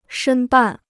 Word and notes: 申办: shēn bàn

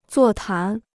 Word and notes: 座谈: zuò tán

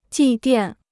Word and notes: 祭奠: jì diàn

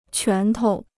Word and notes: 拳头: quán tou

